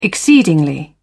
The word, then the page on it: exceedingly: British English